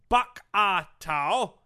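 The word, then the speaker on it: Bokator: Pronounced Bak-a-tau, and it comes from La Bak-a-tau, meaning to pound a lion.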